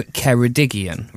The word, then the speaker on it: Ceredigion: My understanding is that it's Radio Ceredigion, right? Ceredigion, spelled Ceredigion, I think. Now, listen to the jingle again. Does it not sound... – You say what? – I think it's Ceredigion. – Ceredigion. – Yeah. Now, I'm no Welsh speaker. – They put in an extra syllable. An extra G in there.